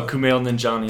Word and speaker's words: Kumail Nanjiani: Oh, Kumail Nanjiani.